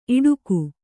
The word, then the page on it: ಈಡಿಗ: kannada